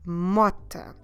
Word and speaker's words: mota: The motorbike in Portuguese is, again feminine, a mota. Okay, that's an easy one. For a change, there is no r sounds on this one. Much easier to pronounce.